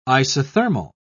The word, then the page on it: isothermal: British English